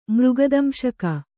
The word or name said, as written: ಮೃಗದಂಶಕ